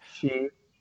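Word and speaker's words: Sheard: But Jabal Shreed, is that how you say his name? – Shreed.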